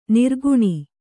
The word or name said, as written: ನಿರ್ಗುಣ